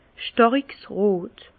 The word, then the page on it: stàriks rot: Bas Rhin; Herrlisheim